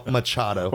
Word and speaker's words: Machado: There's also a guy in this named Mario Macchiato. I think is how you say his name. Or Machado. – Not to be confused with Luigi Cappuccino. – Sorry, Mario Machado. – Oh, Machado, excuse me.